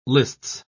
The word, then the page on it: lists: British English